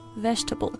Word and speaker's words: vegetable: I guess this word could be challenging because there's four syllables when you look at it, but when we pronounce it, we say it much quicker, so it just sounds like vegetable instead of vegetable.